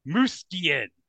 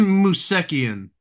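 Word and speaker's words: Moosekian: Duke Muskian. – I think it's Musekian. You hit the emphasis on the wrong syllable, as Mike Myers said in that one movie.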